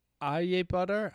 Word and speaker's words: Ajebutter: So I think it's pronounced Aje butter.